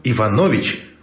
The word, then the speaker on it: Иванович: Причем Иванович — фамилия. Ударение на предпоследнем слоге. Это важно.